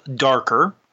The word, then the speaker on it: Dakr: Okay, the app I went for this week is called Darker. It's spelled D-A-K-R.